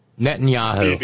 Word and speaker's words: Netanyahu: how do you say his last name? Netanyahu? – Netanyahu.